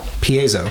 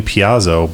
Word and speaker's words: piezo: Piezo. Piezo. – So I was saying piazzo, but it's piezo.